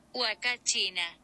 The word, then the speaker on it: Huacachina: Puncachina. – Oh, she had a very thick accent when she pronounced that. – Huacachina. – Oh, Huacachina.